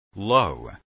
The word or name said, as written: low